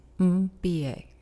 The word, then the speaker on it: Mbie: Really, last name, Mbie is how you say it. Wow, M-B-I-E.